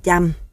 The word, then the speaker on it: Cham: Viết Cham, không có dấu âm Pauthuk, đọc là Cham. Cả người Thái, người Lào hay Khmer cũng đọc là Cham. Nhưng do đặc trưng giọng nói của người Cham, vùng Panduranga, Ninh Thuận và Bình Thuận phát âm nặng hơn, nên Cham biến thành Chăm.